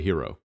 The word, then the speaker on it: gyros: So how would you say that word? – Greek burrito. I would say probably hero. – Okay. – Like a soft, super soft G.